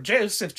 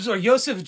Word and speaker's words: Josef: Another theory comes from an MI5 declassified file about Joseph Jacobs, or Joseph Jacobs, because it's spelled J-O-S-E-F, so I'm assuming Joseph.